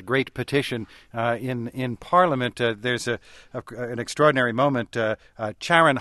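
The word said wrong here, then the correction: Ciarán: Hines, I hope I'm pronouncing his name correctly. – Kieran.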